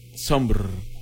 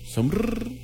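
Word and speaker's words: Sombr: Las 6:36, 6:36, escuchamos a Somr. ¿Cómo se pronunciará el nombre de este artista? Somr. Porque es S-O-M-E-M-B-R, como sombra, pero sin la A. Somr. Somr.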